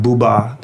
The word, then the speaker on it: Boubat: Bouba, the great French photographer. His name is spelled B-O-U-B-A-T. And Bouba, people called him a peace correspondent, not a war correspondent, but a peace correspondent.